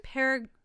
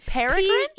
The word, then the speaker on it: peregrine: The Paragon Falcon? – Peregrine Falcon. Peregrine Falcon. Peregrine? – P-E-R-E-G-R-I-N-E.